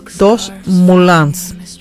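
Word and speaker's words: Deux Moulins: Es el café de Dos Moulins, así es la pronunciación, en Francia.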